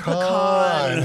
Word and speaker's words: pecan: Oh, I'm sorry, pecan. – you got to put the emphasis on the right syllable.